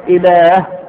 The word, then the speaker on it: إله: الأول، كلمة إله على وزن فعال، لأن فيها ألفا منطوقة غير مكتوبة، هي تكتب همزة مكسورة لام هاء، ولكن عند النطق بين اللام والهاء ألف، لذلك يكتب الواحد مش كده.